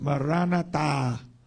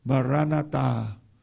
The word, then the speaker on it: Maranatha: In Aramaic, it is pronounced Maranatha, Maranatha, which means the Lord cometh.